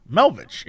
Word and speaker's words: Melvich: Is it spelled like how I think it would be pronounced? Melvich. Yeah. – Yeah, it is.